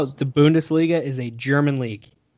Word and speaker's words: Bundesliga: Is Bush, correct me if I'm wrong on the pronunciation, Bundesliga? – You are correct. The Bundesliga